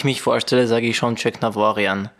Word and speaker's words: Tjeknavorian: Äh, aber original im, in der armenischen Sprache ist es erstens das E gibt's nicht, Check gibt's nicht, das ist Jugnavorjan. Da ist es tatsächlich äh, am Ende die Betonung, aber Check Navarian passt.